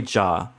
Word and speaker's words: Zhao: I've been saying Chloe Zhao. – Oh, yeah. – It's Chloe Zhao. – Yeah. – Apparently is how it's pronounced.